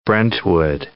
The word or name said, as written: Brentwood